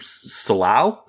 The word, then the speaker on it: Solow: It was Herb Solow? Is that how Solo. – You pronounce his name? Herb Solow.